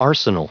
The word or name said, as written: arsenal